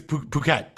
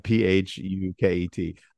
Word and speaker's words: Phuket: I think it's Phuket. – Okay. – This is one right. Where I pronounce it correctly. – I'm going to keep saying fuck it. – Yeah. – Phuket.